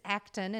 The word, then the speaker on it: Acton: It's because it's Acton and there's so many different ways to spell that. So just think action without an I.